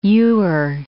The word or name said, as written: ewer